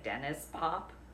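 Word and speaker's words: Denniz Pop: And I always say his name wrong, but I guess it would be like Dennis Pop. Like, it's kind of like Dennis, except instead of an S is a Z.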